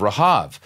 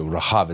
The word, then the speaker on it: Rahab: And that's the Hebrew way, that's how you pronounce her name in Hebrew, Rahab. It's not Rahab. That's the, every time we hear somebody say Rahab, it's like, I do that. It's a Rahab. That's how her name is pronounced.